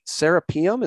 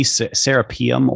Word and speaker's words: Serapeum: Can you remind people Serapeum? Is that how you say that? – It's the Serapeum or the Serapeum. I think that's how they pronounced it.